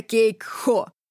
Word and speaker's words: Кейк-Хо: «Ты была в кафе Кейк-Хо». – Джонас делает ударение на «Хо».